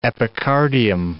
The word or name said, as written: epicardium